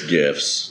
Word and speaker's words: GIF: Or I've actually seen GIFs or GIFs, if you pronounce it wrong. – It's GIFs. – It's GIFs.